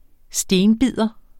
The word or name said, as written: STENBIDER